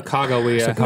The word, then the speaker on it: Sacagawea: Sacagawea.